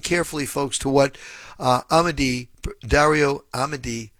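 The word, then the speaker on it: Amodei: This is Anthropic CEO Dario Amodi. Have I pronounced his name correctly, by the way, Professor? – I think it's Amodi. – Amodi. Okay, thank you.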